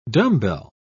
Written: dumbbell

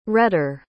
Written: retter

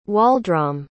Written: Waldrom

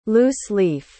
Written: loose-leaf

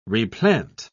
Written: replant

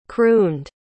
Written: crooned

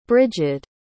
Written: Brighid

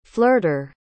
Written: flirter

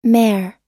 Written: mare

